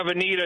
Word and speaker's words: Veneta: Am I saying that right? I've not been to that town. – Yeah, Veneta.